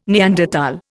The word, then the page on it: Neanderthal: Indian